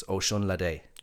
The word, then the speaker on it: Osunlade: So think of it like ocean latte, but it's Osunlade.